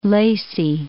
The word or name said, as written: Lacey